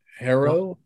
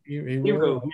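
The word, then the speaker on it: Herro: Tyler Harrow? – Hero.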